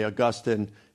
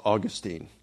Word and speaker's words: Augustine: Some people pronounce that as Augustine. We really don't know how he pronounced it. Typically in the United States, you'll have scholars say Augustine. In Europe, it's Augustine.